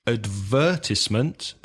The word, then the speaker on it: advertisement: Advertisement, the UK pronunciation, and advertisement, stressing the third syllable.